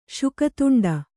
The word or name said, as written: ಶುಕತುಂಡ